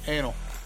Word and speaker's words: Yanal: Y-A-N-A-L. – How do you pronounce that? – Anil.